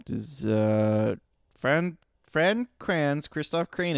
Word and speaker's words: Kranz: Deceptively hard to pronounce. – Fran. – K-R-A-N-Z. – Kranz. – That's completely... – Fran Kranz. – Yes.